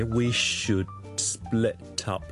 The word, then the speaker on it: should split up: 好啦，第二件事要留意，we should split up 咧，就系个 should 后面有个 d 音，而呢个 d 音咧系需要读出嚟嘅，楞埋后面个 split 嗰个 s 音咧，听落去会系咁样嘅。 We should split up， split up， split up。嗰个读音咧，同我哋之前讲嘅 t s 音嘅发音系一样，好似 it's it's it's。